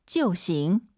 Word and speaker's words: 就行: 就行. – 아이유 사이에 오 넣어서 지어우, 就行 이렇게 암기하지 마시고 제가 이따 패턴, 꿀팁 알려드릴게요.